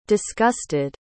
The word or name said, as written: disgusted